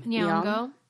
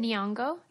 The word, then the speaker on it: Nyong'o: Nyong'o. – How do you say it? – Nyong'o.